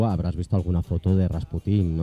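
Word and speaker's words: Rasputín (Распутин): Conocemos Rasputín, lo decimos siempre con este acento agudo, cuando realmente lo que tendríamos que decir es el acento en la llana, Rasputín. – ¿Ah, sí? – Sería, sí, la pronunciación correcta sería Rasputín, pero bueno, vamos a decir Rasputín porque al fin y al cabo es como siempre se, uh-huh.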